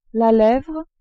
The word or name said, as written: levre